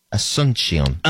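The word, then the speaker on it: Asunción: Asuncion, is that how you pronounce their capital in Paraguay? Asuncion. – Sure.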